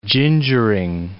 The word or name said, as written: gingering